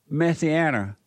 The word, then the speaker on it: Messianic: You're leaving out the Anik. – Oh, Messianic. – Messianic. – Messianic. – Yeah, it's Anik. – The root word is Messiah, or the similar word is Messiah.